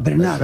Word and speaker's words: Bernhardt: Tienes que pronunciar a la francesa, Bernard.